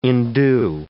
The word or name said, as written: indue